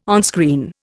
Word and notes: onscreen: Indian

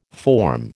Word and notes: forme: American English

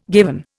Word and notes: given: Indian

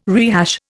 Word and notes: rehash: Indian

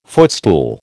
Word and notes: footstool: American English

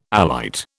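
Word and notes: élite: British English

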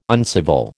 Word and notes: uncivil: American English

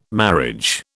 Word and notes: marriage: British English

